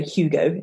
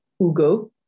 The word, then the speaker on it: Hugo: So some examples of the silent H, for example, the name Hugo. So we would say Hugo in English, but in French it's Hugo.